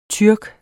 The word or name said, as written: tyrker